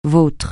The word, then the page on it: vôtre: francais